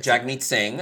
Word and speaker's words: Jagmeet Singh: Jagmeet Singh, the leader of that party.